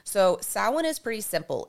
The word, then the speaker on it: Samhain: And if you've never seen how Samhain is spelt, you would not even know that the word is Samhain because it is spelled S A M H A I N, which looks like Sam Hain.